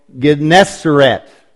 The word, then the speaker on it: Gennesaret: And we'll always struggle with the pronunciation here, but it's called Gennesaret. Gennesaret, sometimes referred to as the Sea of Tiberias.